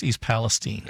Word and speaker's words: East Palestine: So is it pronounced East Palestine or East Palestine? – Well, for me, it was always East Palestine.